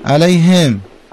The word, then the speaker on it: علیهم: اینا رو من دقت کردم شما مثل هیجیمی تلفظ میکردید، میگفت مثلا علیهم. – خب نباید اینطوری بشه. – آها علیهم. – علیهم.